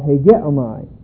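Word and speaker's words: Hegeomai (ἡγέομαι): It's a command of hegeomai. Here is the verb for those of you who want to look it up. H-E, long E, G-E-O-M-A-I. Hegeomai.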